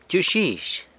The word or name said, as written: btyux guizh